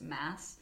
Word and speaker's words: Mads: So M-A-D-S, pronounced just Mass.